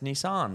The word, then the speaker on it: Nissan: So I have been saying Nissan as my whole life, as probably. Most probably Australians or Americans, I don't know. But obviously being in the film so prevalent, they would obviously have to give the tick of approval. – Yep. – And for all you out there, it is pronounced Nissan.